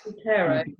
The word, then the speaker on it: Zotero: Another reference tool I used recently called Zotero. Can't pronounce it properly. Zotero. – Zotero.